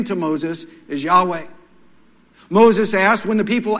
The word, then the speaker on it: Yahweh: In the Old Testament, this capital L, small caps, O-R-D, refers to God's proper and personal name. Theologians of centuries before called it Jehovah in English. Today, they're telling us the right way to pronounce it is Yahweh.